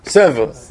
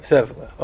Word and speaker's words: Sèvres: How do you pronounce S-E-V-R-E-S? – Sever. – Sever. Sèvres. Okay.